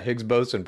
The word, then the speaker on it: Higgs boson: Yeah, Higgs boson particle. – Chad's into this shit. The Higgs boson particle, dude. Higgs boson, brother. Higgs boson, dude. Higgs boson, brother. Higgs boson, dude. Higgs boson, brother.